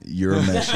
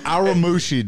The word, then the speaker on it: Urameshi: Urameshi. – Oh my god. – Urameshi, dude.